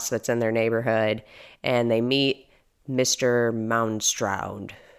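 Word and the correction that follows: Moundshroud: Is that how you pronounce it? – Mound Shroud. So. – Shroud. Mound Shroud.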